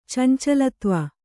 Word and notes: ಚಂಚಲತ್ವ: Kannada

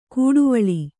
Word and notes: ಕೂಡುವಳಿ: kūḍuvaḷi

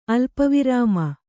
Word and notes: ಅಲ್ಪವಿರಾಮ: alpavirāma

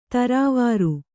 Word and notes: ಥರಾವರಿ: Kannada

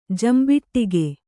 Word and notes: ಜಂಬಿಟ್ಟಗೆ: jambiṭṭage

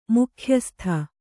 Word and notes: ಮುಖ್ಯಸ್ಥೆ: mukhyasthe

